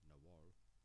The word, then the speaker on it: Nahual: Werewolves in Mexico are known as the Nahual or Nugal. Both are pronounced Nahual, so I said it wrong.